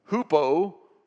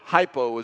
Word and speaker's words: hypo: Hupo, or hypo as we pronounce it, means low, and hyper means high.